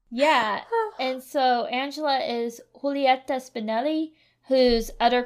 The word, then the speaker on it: Angela: I'm going to say Angela because I feel like I'm mispronouncing Angela. But they have a hard G when they say her name. It sounds much prettier in Spanish.